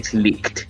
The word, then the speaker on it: Licht: How is it spelled? – LICHT. – Licked. It's Licked. It's German.